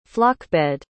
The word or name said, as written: flockbed